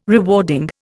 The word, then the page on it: rewarding: Indian